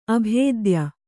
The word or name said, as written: ಅಭಿಧಾಯಿ